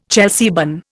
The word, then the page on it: Chelsea bun: Indian